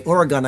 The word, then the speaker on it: Oregon: Oregon, I said it correctly that time.